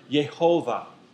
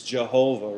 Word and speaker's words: Yehovah: That Hebrew word is the word Yehovah, or what we would pronounce as Jehovah, right? Yehovah, right? Which means the existing one, the eternal one.